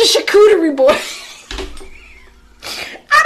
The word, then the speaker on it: charcuterie: Not, am I saying that right? It's charcuterie. Somebody type it. it's called charcuterie.